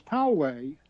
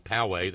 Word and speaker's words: Poway: Because Poway, that's the pronunciation. – Okay, Poway, thank you, Poway. I had a 50-50 chance. I blew it.